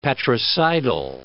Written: patricidal

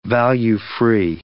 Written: value-free

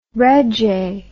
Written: regge

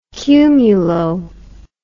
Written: cumulo

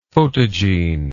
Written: photogene

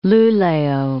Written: Lulea

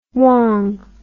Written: wong

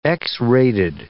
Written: X-rated

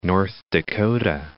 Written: North Dakota

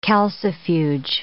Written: calcifuge

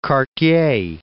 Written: quartier

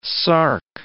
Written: sark

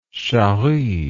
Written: chari

